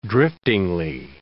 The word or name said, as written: driftingly